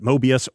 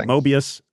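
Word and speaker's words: Möbius: and also pronounced it Mobius, or if you're in England, Mobius. So there you go. So I'm going to pronounce it Mobius.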